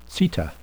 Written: seta

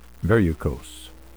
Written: verrucose

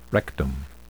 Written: rectum

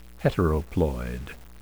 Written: heteroploid

